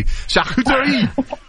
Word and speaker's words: charcuterie: You pronounced it so lovely. Charcuterie.